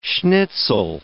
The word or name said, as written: schnitzel